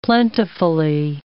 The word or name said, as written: plentifully